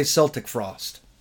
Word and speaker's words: Celtic Frost: Now, how familiar are you with, and some people pronounce this differently. I've heard it both ways. – Okay. – Celtic or Celtic Frost? And what's your pronunciation of it? – I would say Celtic Frost. – That's how I've always done it, yeah. – Like the Boston Celtics.